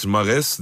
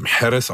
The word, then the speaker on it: Mahrez: Rabiot, Riyad Mares, der äh, in Wirklichkeit Mahrez ausgesprochen wird, habe ich irgendwann mal gelernt.